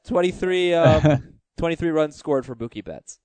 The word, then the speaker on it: Mookie Betts: His name rhymes with Kookie Metz. Yes, yeah. Mookie Betts.